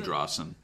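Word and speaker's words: Drossin: Is that how you say his name? We talked about him in Comic Zone. – I say Drossen. – Drossen is pretty much how I say it too,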